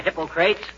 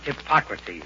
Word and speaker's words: Hippocrates: Hi, Hippocrates. – If you are referring to the father of medicine, McGee, it's pronounced Hippocrates.